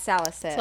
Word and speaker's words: salicylic: Salicylic? – It's like in all like kinds of acne washes. Yeah, sure. – Oh, okay, yeah, it's salicylic.